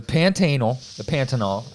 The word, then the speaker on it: Pantanal: So the Pantanal, as it is properly pronounced, is larger than 29 U.S.